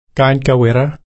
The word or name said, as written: kainka-wirra